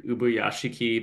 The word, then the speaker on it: Ubuyashiki: of the like Demon Slayer Ubuyashiki mansion explosion.